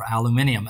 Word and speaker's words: aluminium: And we're starting to see this play out in the real economy, in aluminum. Or aluminium, as some listeners may prefer to pronounce it,